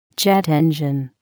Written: jet engine

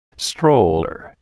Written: stroller

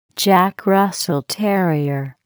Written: Jack Russell terrier

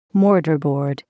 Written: mortar board

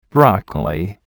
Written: broccoli

